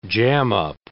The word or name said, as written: jam-up